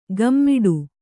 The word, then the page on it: ಘಮ್ಮಿಡು: ghammiḍu